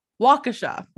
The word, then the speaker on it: Waukesha: on May 31st, 2014, a cyclist on Big Bend Road in Waukesha. There we go. That is how you pronounce it.